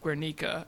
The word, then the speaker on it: Guernica: He's seen the, I can't even remember how to pronounce, is it Guernica or Guernica? We always pronounce it Guernica in art history, oddly enough, but I think it's actually pronounced Guernica.